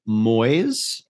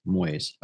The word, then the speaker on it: muis: Pronounced moys, rhyming with boys, is Dutch, M-U-I-S, moys, and then pronounced more like miesh, M-E-E-S-H phonetically.